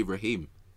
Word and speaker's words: Raheem: Yeah, because they love putting the extension on his, like, bro, it's Raheem, isn't it? Just say Raheem.